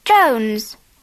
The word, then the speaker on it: Jones: My family name's Jones. – Paula Jones. Can you spell that? – Yes. J-O-N-E-S.